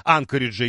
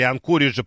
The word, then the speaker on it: Анкориджа: Виталий ждет теперь дух Шэньчжэня, вслед за духом Анкориджа или Анкориджа, по-разному люди ставят ударение.